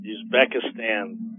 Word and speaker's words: Uzbekistan: How do you pronounce that? – Uzbekistan.